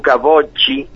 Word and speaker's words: Bocci: En este caso el disco se llama Ahora, pertenece a Luca Bocci, que se escribe con doble C, así que me explicaron, gente que habla muy bien italiano, que si es doble C es Bocci, sí. – Con B larga.